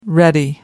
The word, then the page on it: ready: American English